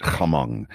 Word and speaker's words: Hmong: So it's been a while since I've seen the movie, but you know, he's really hostile to his Hmong. That's the correct way to pronounce it. Hmong.